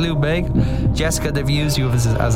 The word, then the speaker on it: Lou Bega: Lou Bega, I cannot pronounce it. – I think you've pronounced it correctly. Lou Bega Mambo Number 5.